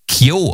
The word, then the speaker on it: KEO: Ich glaube, sie werden Kyo ausgesprochen, obwohl sie K-E-O, also anders geschrieben werden, als man es denkt, aber leitet sich vom Nachnamen.